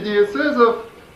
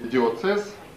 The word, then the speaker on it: диоцез: Правители диецезов назывались викарии, викарии и диецезум, или экзархи. Это все я говорю про светскую терминологию. Видите, вся церковная терминология, не вся, конечно, но вот диецез, например, или диоцез, сегодняшним произношением, тоже взята из гражданства.